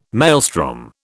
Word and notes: maelstrom: British English

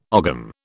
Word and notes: ogam: British English

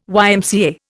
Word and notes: YMCA: Indian

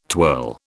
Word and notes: twirl: British English

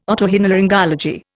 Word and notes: otorhinolaryngology: Indian

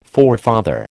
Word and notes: forefather: American English

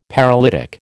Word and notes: paralytic: American English

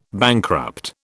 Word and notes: bankrupt: British English

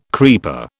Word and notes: creeper: British English

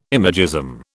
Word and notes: imagism: British English

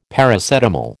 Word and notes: Paracetamol: American English

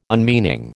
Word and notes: unmeaning: American English

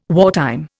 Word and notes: wartime: Indian

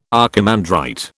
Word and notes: archimandrite: British English

